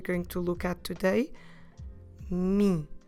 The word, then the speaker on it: mim: It is similar to the English pronoun me, but this time we need to make it nasal. So first of all, como se escreve em português me? Me. M-I-M. Me. Notice that when we pronounce this word, we need to keep the mouth open at the end. So don't close your lips.